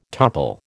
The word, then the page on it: topple: American English